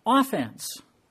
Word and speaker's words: offense: The word also can be used in another way, and that is offense. When you put the emphasis on the first syllable, if you can remember how that works.